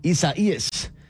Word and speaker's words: Isaias: Isaias. First of all, that is how you say it.